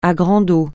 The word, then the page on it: à grande eau: francais